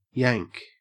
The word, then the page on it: yank: Australian English